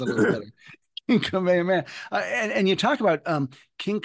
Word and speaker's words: Kamehameha: Kamehameha, I think, is what it Kamehameha.